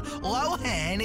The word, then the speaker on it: Lohan: Yeah, we've been pronouncing her name wrong this whole time. It's not Lohan, it's... – Lohan.